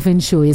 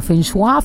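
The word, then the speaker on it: feng shui: Oye, de hecho creo que no se pronuncia fenshui, es como fenshua, algo así.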